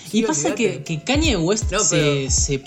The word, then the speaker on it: Kanye West: Kanye West. Kanye, West. – Kanye, El marido de Kim Kardashian.